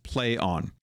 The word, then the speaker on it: Plaion: And they kind of said that it's more impactful if they go with Play On, or P-L-A-I-O-N. Ion, which looks like Pion or Plion, but it's play on, which is a weird spelling to go with.